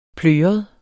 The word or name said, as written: pløret